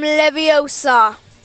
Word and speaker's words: Leviosa: Wingardium Leviosa.